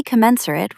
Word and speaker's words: commensurate: Your grade on a project is usually commensurate with the effort you invested. Stress the second syllable. K-men-sure-it. Try saying it.